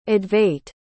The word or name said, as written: advait